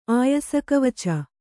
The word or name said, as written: ಆಯಸಕವಚ